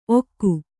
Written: ಒಕ್ಕು

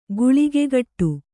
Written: ಗುಳಿಗೆಗಟ್ಟು

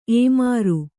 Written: ಏಮಾರು

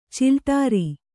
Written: ಚಿಲ್ಟೇರಿ

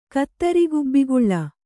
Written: ಕತ್ತರಿಗುಬ್ಬಿಗುಳ್ಳ

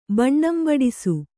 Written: ಬಣ್ಣಂಬಡಿಸು

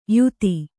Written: ಯುತಿ

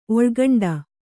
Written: ಒಳ್ಗಂಡ

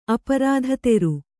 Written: ಅಪರಾಧತೆರು

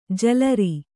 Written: ಜಲರಿ

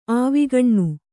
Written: ಆವಿಕಣ್ಣು

